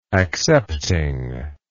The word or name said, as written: accepting